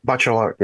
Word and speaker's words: Bachelard: And it started with, I might say this name wrong, Bachelard. – Bachelor, Gosh, you're right.